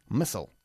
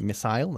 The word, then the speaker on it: missile: Y los norteamericanos, por cierto, lo pronuncian missile. – Okay. – Missile. We say missile, they say missile.